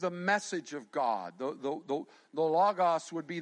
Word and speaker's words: logos: So, we're going to, the word here comes from a Greek word that's, some pronounce it logos or logos. I'll say logos, L-O-G-O-S.